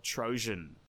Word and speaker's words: Troezen: and he founded the city of Trojan. Hey, guys, from the future here. Just a heads up that I will constantly be mispronouncing the word Trojan. Spelled T-R-O-E-Z-E-N.